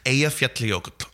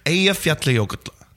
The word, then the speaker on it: Eyjafjallajökull: And there's wonderful clips you can watch online of newscasters from all over the world desperately trying to pronounce it, but it's Eyjafjallajökull. – One more time for the listeners. – Eyjafjallajökull.